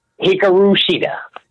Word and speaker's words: Hikaru Shida: Hikaru Shida.